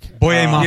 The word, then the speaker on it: Boye Mafe: How do we pronounce his name? – Oh yeah, the second round draft pick, Boye Mafe.